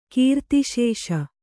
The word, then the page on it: ಕೀರ್ತಿಶೇಷ: Kannada